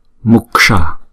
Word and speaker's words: Muksha: Rồi chữ này đọc là Muksha, Muksha. Chữ S cong lưỡi lại búng ra S, S, Muksha.